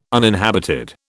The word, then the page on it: uninhabited: British English